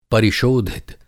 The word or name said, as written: परिशोधित